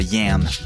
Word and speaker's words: Yen: Le morceau s'appelle Yen. Ça se prononce comme ça, Yen.